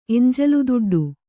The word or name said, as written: ಎಂಜಲುದುಡ್ಡು